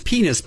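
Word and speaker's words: PEN15: I believe the name of this show is Penis, but it's spelled P-E-N-15.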